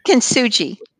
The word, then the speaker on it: Kintsugi: Kintsugi? How do you say it? – Kintsugi.